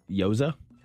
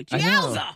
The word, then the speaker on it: gyoza: G-Y-O-Z-A. I'm going to assume the G is silent. Yoza? – Gyoza, baby? No, I'm sorry. – That means you're wrong. – The G is not silent. Gyoza.